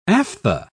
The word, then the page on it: aphtha: British English